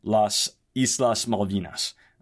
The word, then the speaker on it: Las Islas Malvinas: has also claimed the islands and calls them Las Islas Malvinas.